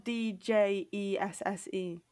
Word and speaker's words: Djesse: De Jesse, Volume 3. Uh, Haim. – Degrassi? Degrassi? Degrassi? – No, it's spelled D J E S S E, like D Jesse.